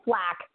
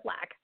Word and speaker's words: Flach: We have author Christina Flatch. Am I pronouncing your name right? – Hi, my name is Flack, Christina Flack.